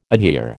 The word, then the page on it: adhere: American English